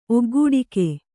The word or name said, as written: ಒಗ್ಗೂಡಿಕೆ